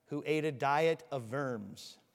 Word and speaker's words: Worms: It looks like worms if you just read it out in English. But you would say Worms, okay? Because the W is pronounced like a V in German.